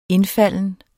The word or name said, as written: indfalden